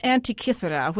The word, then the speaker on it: Antikythera: Yes, well, they call it Antikythera, which is after a Greek island because that's where it was found.